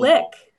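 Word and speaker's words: Glück: And Glick too. It's, I've been told Glick. – I've been told there's an accent.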